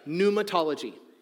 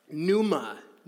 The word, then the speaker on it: Pneumatology: Pneumatology. Pneumatology. I know. I played you with the whole starts with a P thing. I did it on purpose. So, silent P. Pneuma. Pneuma.